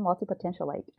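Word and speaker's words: multipotentialite: Potentialite, is that how I pronounce yes, it? Yes, mm-hmm, multi potentialite.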